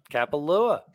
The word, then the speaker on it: Kapalua: Kapalua? – Kapalua. – Hell yeah. The Kapalua Plantation course. It's going to be a birdie fest. – It is going to be a birdie fest. It always is. Kapalua Plantation course, 7,600 yards roughly.